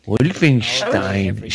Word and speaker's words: Wolfenstein: Now, is it Steen or Stein? – Steen. – Wolfenstein. Steen. Yeah, Steen.